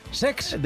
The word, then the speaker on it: Cesc: No lo he dicho mal, es Sex. – De Chex.